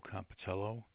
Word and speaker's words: Compitello: It should be Joe Compitello, 140 Homestead Road, Oakdale, New York, 11769.